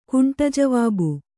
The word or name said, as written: ಕಂಟಜವಾಬು